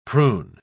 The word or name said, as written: prune